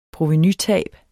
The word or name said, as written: provenutab